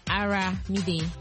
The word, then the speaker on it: Aramide: Est-ce que c'est Armide ou Armide? Is your name Armide ou Armide? – Aramide. – Aramide. – Yes. – I knew it. I told you. Je vous ai dit, c'est comme Olomide. Mais seulement c'est Aramide.